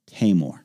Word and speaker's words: Taimur: Spells his name T-A-I-M-U-R, and he said, here's how you say it, Tamor. I was like, oh, I got it. How can I say your last name? B-H-U-T-T-A.